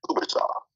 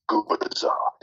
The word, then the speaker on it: Gubicza: This TV announcer came in to Danny and says, Man, how do you say this guy's name? Because I can't figure this out. And he says, It's Gubicza. Gubicza.